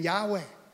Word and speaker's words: Yahweh: YHWH, that's, you know, and the theologians, they're still, you know, uncertain of how you actually pronounce it, because the Jews have been so secretive about this. But we believe it's the name Yahweh.